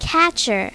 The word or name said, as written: catcher